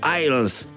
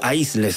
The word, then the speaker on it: Aisles: con el grupo Isles, o Aisles, si lo quieren decir literalmente, pero Isles es como la pronunciación correcta.